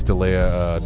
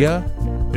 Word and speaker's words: D'Elia: Mostly it was the Chris D'Elia 9.5 minute. – You refuse to say his name correctly. – D'Elia? – It's D'Elia.